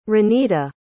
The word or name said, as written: Ranita